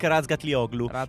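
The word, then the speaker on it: Toprak Razgatlıoğlu: Toprak Razgatlioglu.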